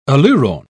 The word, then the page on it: aleurone: British English